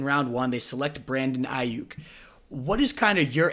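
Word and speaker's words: Aiyuk: Is it Brandon Ayuk? Did I say that right? – Ayuk. – Ayuk, okay, Brandon Ayuk.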